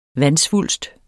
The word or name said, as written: vandsvulst